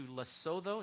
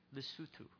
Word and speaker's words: Lesotho: She's in a couple of weeks heading out to Lesotho. Is that how you pronounce it? Lesotho? Lesotho. Okay, I was totally wrong.